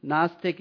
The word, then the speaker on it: Gnostic: Gnostic is, you don't pronounce the G on the way on the top line.